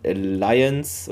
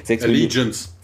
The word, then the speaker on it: Allegiance: Und deshalb gab es hier dreimal die Director Series, äh, einmal für, ich kann es aber nicht aussprechen, Alliance oder so, Allegiance. Allegiance.